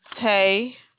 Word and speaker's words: Taye: Tay. – Spell that. – T-A-Y-E.